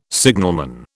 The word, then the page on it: signalman: British English